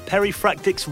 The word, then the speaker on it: Perifractic: Welcome to the show, Christian Simpson from, now let me make sure I say this right, Perifractic's Retro Recipes.